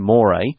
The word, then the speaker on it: mores: Now, it looks like the word more, but it's pronounced more.